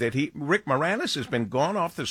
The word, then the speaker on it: Moranis: Actor Rick Moranis, am I saying his name right? Moranis? – Rick Moranis, yeah. Moranis.